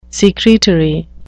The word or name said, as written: secretory